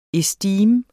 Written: estime

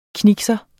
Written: knikser

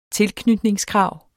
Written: tilknytningskrav

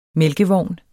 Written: mælkevogn

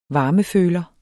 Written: varmeføler

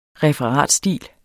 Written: referatstil

